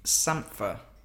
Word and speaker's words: Sampha: It rhymes with panther. – Yeah. – So, Sampha, by the time you're listening to this, he released an album last Friday.